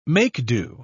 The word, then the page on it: make-do: British English